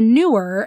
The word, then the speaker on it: Neewer: So this that I'm sharing with you, my fifth favorite thing, is the newer, and that's spelled N-E-E-W-E-R.